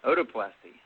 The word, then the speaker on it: otoplasty: Well, today's discussion is, how do you pronounce this word, otoplasty? – Otoplasty.